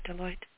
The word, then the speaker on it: Deloitte: Karen, did I say that right, Deloitte? – You did. You got it.